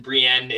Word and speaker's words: Brien: Justin Brien. It's spelt like O'Brien, but just without the O.